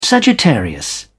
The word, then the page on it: sagittarius: British English